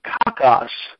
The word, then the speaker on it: kakos: It's translated from the Greek word kalos, and it's spelled or transliterated K-A-L-O-S, kalos. And the word evil is translated from a Greek word, kakos, transliterated K-A-K-O-S.